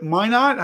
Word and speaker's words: Minot: Is it Minot? How do you pronounce that? – Yep. – Minot Lighthouse is famous.